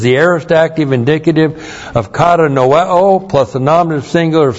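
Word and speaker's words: Kata noeo (κατανοέω): The first verb, kata noeo. K-A-T-A-N-O-E long O.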